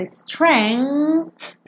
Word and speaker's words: strength: Esse G aqui é mudo. Então você pensa strength e bota aquele som.